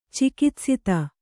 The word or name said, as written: ಚಿಕಿತ್ಸಿತ